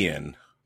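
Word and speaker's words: Iain: It might be Ian, but it's definitely spelled with an extra I in there. It's I-A-I-N.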